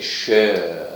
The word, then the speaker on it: شیر: یای مجهول به این شکل تلفظ می شده شر